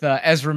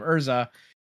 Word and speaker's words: Ezra: But in any case, that's what's going on with Ezra Miller. Have to remember to say his name right, because otherwise I'm just going to keep calling him Urza.